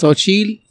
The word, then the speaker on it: Xochitl: But some other versions of it say that her name was Xochitl, which is spelled... – Xochitl, yes. Yeah, X-O-C-H-I-T-L.